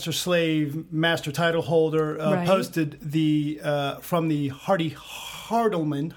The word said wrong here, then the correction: Hardy Haberman: Oh, I'm not saying that right. – Hardy Haberman.